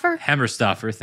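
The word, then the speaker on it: Hemmerstoffer: But if the render is accurate, which, you know, Steve Heffer, I forget how to say his name. – Hammerstoffer, thank you.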